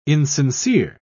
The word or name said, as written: insincere